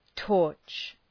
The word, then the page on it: torch: tɔ:rtʃ